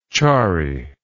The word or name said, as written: charry